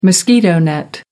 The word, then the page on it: mosquito net: American English